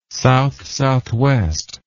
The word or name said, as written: south-southwest